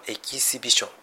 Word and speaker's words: exhibition: ちなみに発音はエキシビションというふうに濁らないんですね。これが正式。